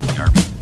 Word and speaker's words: D'Arby: When I see a D apostrophe, I always say D as something, because that's how you say that. Like D'Angelo and that sort of thing. You got to think about like Terrence Trent D'Arby. – D'Arby, yeah. That's how you say it, D'Arby.